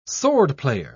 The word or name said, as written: swordplayer